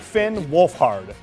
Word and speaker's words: Wolfhard: That's actor Finn Wolford. Am I saying that right? I, if I were him, I'd go with Wolf. – Wolfhard. But, you know. All right, Finn Wolfhard. Finn Wolfhard.